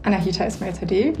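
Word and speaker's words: Anahita Esmailzadeh: Also mein Name ist Anahita Ismail Tadeh, wie du es gerade perfekt ausgesprochen hast.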